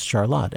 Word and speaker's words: Charlotte: It's spelled the way Charlotte is, but it is pronounce Charlotte. And that's one of those words, you know, like when you have Milan and Milan. If you're from central Michigan, if people say Charlotte, they know you're local.